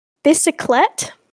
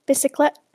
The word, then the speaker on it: bicyclette: bicyclette. I think I'm saying that right. Like bicycle. Mm. TTE, bicyclette. – TTE, so it's French.